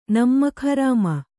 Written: ನಿಮಕ್‍ಹರಾಮ

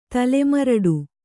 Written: ತಲೆಮರಡು

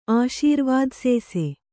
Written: ಆಶೀರ್ವಾದಸೇಸೆ

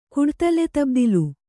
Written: ಕುಡ್ತಲೆತಬ್ದಿಲು

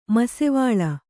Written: ಮಸ್ಸೀವಾಲ

